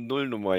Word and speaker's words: Nullnummer: Eine Nullnummer, ja, genau. – Mit Betonung auf Null.